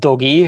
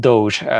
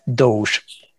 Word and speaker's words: Doge: Do- dogének írják, de nem, nem Dogi, hanem Doge a hivatalos kiejtése.